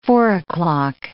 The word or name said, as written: four-o'clock